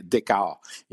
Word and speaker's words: Dichow: Ça s'écrit D-I-C-H-O-W, mais il faut prononcer Descartes.